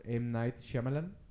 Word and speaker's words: M. Night Shyamalan: Que es una serie de películas dirigidas por M. Night Shyamalan, ahora sí lo dije bien, que todo empezó aquel buen verano del 2000, cuando salió la película de Unbreakable.